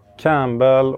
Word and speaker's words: Campbell: Campbell och... – Campbell. – Och, ska inte uttala P här.